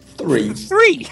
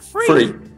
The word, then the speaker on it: three: So what's the proper way to say it? Go on. – Three. – It's not hard, mate. – And what am I saying? – Free. Like you're free Willy or something like that.